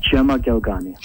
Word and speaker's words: Gemma Galgani: In fact, Saint Gemma Galgani, am I saying that right, or Gemma? How do you pronounce her name? – Gemma Galgani.